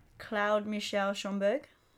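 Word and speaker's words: Claude-Michel Schönberg: The music is by Claude Michel Schoenberg. – Good pronunciation. Yeah. I'm glad you did some French at school.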